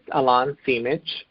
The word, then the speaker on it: Alain Simic: It's Alain Simic, uh, and that is spelled A L A I N S I M I C.